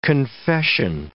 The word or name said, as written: confession